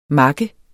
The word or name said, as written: makke